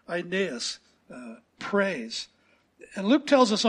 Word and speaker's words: Aeneas: And so I did a little research and discovered here's the correct pronunciation, Aeneas. Let's just say that together, Aeneas.